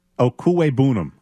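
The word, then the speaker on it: Okwuegbunam: Okuwebunam, as I believe how you say it.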